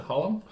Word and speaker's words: Hallam: Sam Hallam, or how do you pronounce it? Hallam? – Yeah. That's how I pronounce it.